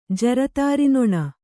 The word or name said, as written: ಜರತಾರಿನೊಣ